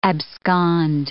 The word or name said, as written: abscond